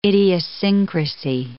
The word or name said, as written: idiosyncrasy